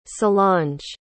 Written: solange

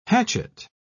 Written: hatchet

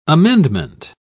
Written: amendment

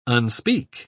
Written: unspeak

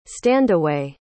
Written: standaway

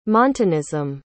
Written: montanism

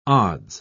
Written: odds

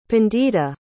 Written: pandita

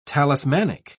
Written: talismanic

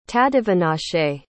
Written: tadiwanashe

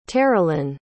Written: taralynn

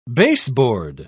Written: baseboard

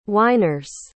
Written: whiners

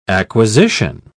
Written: acquisition

